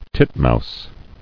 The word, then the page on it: Titmouse: tit·mouse